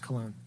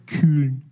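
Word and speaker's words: Köln: So he gets to Cologne, Germany, and it's spelled K-O-L-N, pronounced Cologne, or Kuhn, but I can't pronounce it that way, so I said Cologne.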